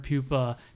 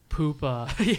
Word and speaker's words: Puppa: and both of them had clips of Darren Puppa taking a puck off the glove. – You're saying it wrong. – Puppa.